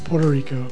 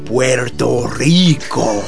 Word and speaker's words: Puerto Rico: And this week in the Craigslist Corral, we're taking a trip down to Puerto, say it right, Puerto Rico. – Puerto Rico.